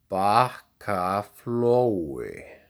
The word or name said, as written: Bakkaflói